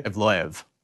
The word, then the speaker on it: Evloev: Evloev.